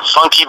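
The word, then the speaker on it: Funkee: Funky Boy spelled with two E's, F-U-N-K-E-E, and a separate word, B-O-Y.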